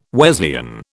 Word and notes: Wesleyan: British English

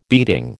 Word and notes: beating: American English

